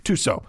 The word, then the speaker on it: Tussaud: Tussaud. – I'm sorry. Why do I always say Tussaud? – Yes. – Tussaud. – That's right.